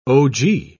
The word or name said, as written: ogee